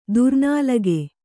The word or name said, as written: ದುರ್ನಾಲಿಗೆ